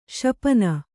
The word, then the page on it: ಶಪನ: śapana